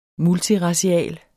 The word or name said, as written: multiracial